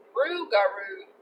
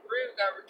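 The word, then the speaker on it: Rougarou: Loup-garou. Rugaroo, that's how you say it. Rugaroo, because it's like loup-garou. Rugaroo roam the southeastern Louisiana bayous.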